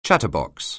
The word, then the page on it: chatterbox: anglais